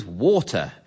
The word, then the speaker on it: Walter: And the Queen's nickname for him, in fact, was Water, as his Devonian accent meant he did not pronounce the L in his first name.